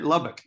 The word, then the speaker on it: Lubbock: Lubbock? – Yeah, yeah, yeah.